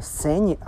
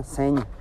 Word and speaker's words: Senj: Äh, das äh, spricht man Senj aus. Also so wie Njam, so wie der Anfang von Njam, ist das Ende von Wort, ne? Senj.